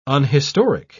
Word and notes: unhistoric: British English

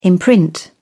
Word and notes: imprint: British English

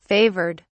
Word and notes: favoured: British English

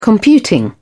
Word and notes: computing: British English

